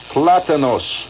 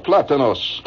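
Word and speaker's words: plátanos: How do you pronounce it? Plantones. Plantains. I'm getting the official quote here. Yeah? Platanos. Platanos. Gracias, senor. Platanos. Very good.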